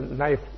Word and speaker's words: knife: Sometimes that word is silent. You spell knife, you start off with what? K. Now, someone doesn't know about it, what level? You will say what level? You're wrong, is it?